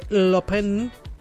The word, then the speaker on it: Lupin: Bueno, de series, ok, hablemos de series, porque Netflix ha confirmado la fecha del estreno de Lupin parte 4, así se pronuncia, así, porque es francés.